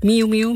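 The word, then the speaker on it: Miou-Miou: En die kennen we misschien beter als Miu Miu. Zeg ik dat goed? Miu Miu, miauw, miauw.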